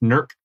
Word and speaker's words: Newark: I grew up near a town in Ohio that's spelled Newark, like as in New Jersey, but it's pronounced Nurk. Like, I hate that.